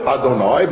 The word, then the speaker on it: Adonai: as we pronounce it, Adonai, but also as it's written, Yud-Kei and above.